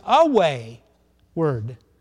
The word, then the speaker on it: wayward: And wayward, the way it used to be pronounced, was away-ward.